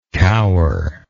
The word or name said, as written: coweringly